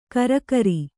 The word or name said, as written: ಕರಕರಿ